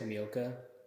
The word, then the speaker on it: Tomioka: Tomioka.